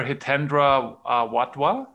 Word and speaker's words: Hitendra Wadhwa: His name is Professor Hitendra Wadhwa. Am I saying this correctly? – Yep,